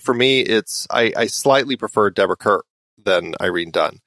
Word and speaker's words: Deborah Kerr: And it is Deborah Kerr.